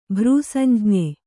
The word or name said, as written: ಭ್ರೂಸಂಜ್ಞೆ